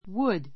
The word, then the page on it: would: 弱形 wəd; ウド; 強形 wúd